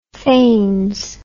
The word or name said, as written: fains